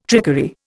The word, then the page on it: trickery: Indian